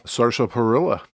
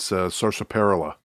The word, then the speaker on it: sarsaparilla: But additionally, I've got an Earps original sarsaparilla. – That's great. – It's an orca beverage. – How is it? – Oh, I believe I mispronounced it. It's sarsaparilla.